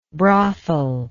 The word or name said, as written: brothellike